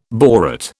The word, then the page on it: borate: British English